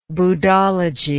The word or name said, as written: buddhology